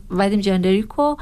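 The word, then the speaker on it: Vadim Jendreyko: توی داکینکوبیتر یه پرودوسری پیدا کردیم که الان پرودوسر سوئیسی فیلم هست، ودیم رندریکو با تلفظ خودش ودیم جندریکو،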